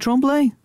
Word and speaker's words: Tremblay: I think it's Tremblay, isn't it Tremblay? I don't think I'm mispronouncing it. This isn't a Bernard type situation. I'm pretty sure it's Tremblay.